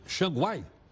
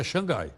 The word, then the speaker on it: Xangai: Pequim e Xangai, Xanguai. Não, acho que é Xangai. Xanguai não é não.